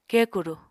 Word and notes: kekuru: Apatani

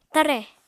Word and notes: tare: Apatani

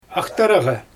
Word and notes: axtaraGa: Xyzyl

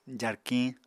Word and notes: jarkiñ: apatani

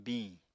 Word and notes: biñ: Apatani

